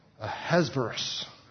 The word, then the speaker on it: Ahasuerus: Ahasuerus. – I have read that if you say it properly in the way it was said, it should sound like a sneeze. Ahasuerus!